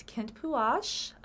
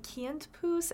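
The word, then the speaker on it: Kintpuash: As mentioned in the first episode, he was born as Kent Puash, also pronounced kind of like Kent Poos.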